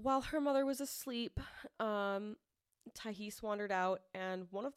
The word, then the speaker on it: Tiahease: So he gets out, and a 10-year-old girl named Tyhese Jackson. – How do you spell it? – T-I-A-H-E-A-S-E. Tyhese. Okay. That is how they said it in the documentary.